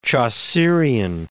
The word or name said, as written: Chaucerian